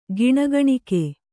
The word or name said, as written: ಗಿಣಿಗಿಣಿಕೆ